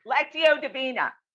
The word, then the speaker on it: Lectio Divina: You know, so like, how does Lectio Divini? – Lectio Divina.